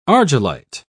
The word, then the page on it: argillite: British English